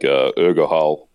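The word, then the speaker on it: Urgehal: Like Urgohal or wherever that's pronounced is another one. – I think that's actually how it's pronounced.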